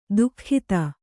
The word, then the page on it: ದುಃಖಿತ: duhkhita